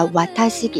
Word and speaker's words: 私: 瓦塔西，我写成私人的“ 私”这个汉字，但是发音发成瓦塔西，瓦塔西。然后瓦塔西哇，那这个哇的话呢是助词，起到提示主语的作用，把瓦塔西给提示出来，给它拽出来哈，提示主语的作用。